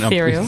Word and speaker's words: ethereal: And what I loved about those scenes, the stabbing scenes were my favourite, was this is beautiful etherical? It's not a word. – Ethereal. – It's not just me mispronouncing things, ethereal. – Is it? – Ethereal. – Yes.